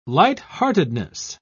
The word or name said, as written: lightheartedness